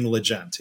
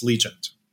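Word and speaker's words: Legent: I learned they say Legend. – Legend. – I'd been saying it wrong. I'd been saying Legend. It's Legend.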